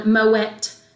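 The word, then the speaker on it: Moët: And by the way, for anyone, Mouet... You do pronounce the T. – Yes, you do. – It's Flemish. Yeah.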